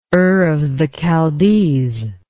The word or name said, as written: Ur of the Chaldees